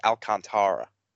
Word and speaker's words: Alcantara: Apparently, we've been saying that wrong. It's uh, apparently I've been seeing people on Twitter freaking out because now that Sandy's getting good, we have to correct ourselves and say that it's Alcantara.